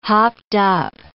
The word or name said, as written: hopped-up